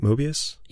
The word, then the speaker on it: Moebius: Moebius? – Yeah, yeah.